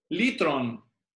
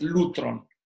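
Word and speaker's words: litron (λύτρον): Se pronuncia litron en griego, la pronunciación moderna. Sí, algunos dicen que es lutron.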